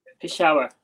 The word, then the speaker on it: Peshawar: Now, the teams left in the mix uh, for those playoffs is the Multan, uh, Multan, Karachi, Lahore, and Peshawar. Peshawar. And I, have said that terribly. So how do you say that? Peshawar. Peshawar. I was like, I was practicing, I just couldn't get it right.